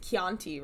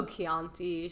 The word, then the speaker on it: Chianti: You said Chianti wrong. – Oh, Chianti, Chianti.